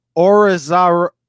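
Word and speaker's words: Arozarena: I can't pronounce his last name. I never can pronounce his last name. Randy Orizarena. – Not yet. There you go.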